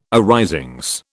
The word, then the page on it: arisings: British English